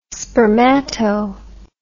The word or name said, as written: spermato